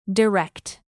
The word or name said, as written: direct